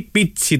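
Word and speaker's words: Pizzi: Καλό υπόλοιπο Κυριακής, ανεβασμένος ο Πίζη, Πίτσι το λέει ο Πίζη, έτσι μας είπαν ότι προφέρεται το όνομά του,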